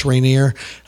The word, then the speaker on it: Reanier: But that's just my name, Dennis Rainier, which my last name is spelt a little unique, R-E-A-N-I-E-R.